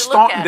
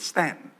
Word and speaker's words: Staunton: While we're off the track there, I preach at this place called Stanton, Virginia. Now, you think it would be spelled S-T-A-N-T-O-N. It's S-T-A-U-N-T-O-N. So that's Staunton. It's Staunton to everybody else. But when you're there, those people say it's Stanton.